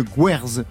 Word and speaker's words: Gwerz: Dénès, vous signez un, recueil de Guerz, j'ai bien prononcé Oui, c'est ça, oui.